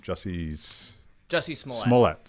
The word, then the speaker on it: Jussie Smollett: Jussie Smollett.